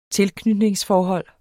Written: tilknytningsforhold

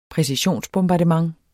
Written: præcisionsbombardement

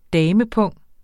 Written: damepung

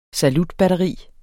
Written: salutbatteri

